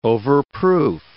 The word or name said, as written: overproof